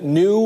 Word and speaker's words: nu: The World Health Organization named this variant Omicron instead of the next letters in the Greek alphabet, nu or xi. In a statement to the Associated Press, they said they skipped nu because it sounds like the word new and that Xi is a common last name.